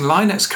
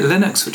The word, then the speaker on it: Linux: And many congratulations, yeah, by the way, on pronouncing Linux correctly. I keep on pronouncing it Linux, which I know is wrong.